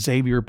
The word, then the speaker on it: Xavier: This character's name was Magneto, along with Professor Xavier, which should really be pronounced Xavier, but it isn't.